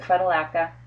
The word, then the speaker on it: Phytolacca: If you can look back and see that the tonsils are red or inflamed, then, you know, I like to give Phytoloca. Phytoloca, or some people pronounce it Phytolacca,